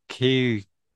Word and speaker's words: Keoghan: It's got your favorite, Barry Keoghan.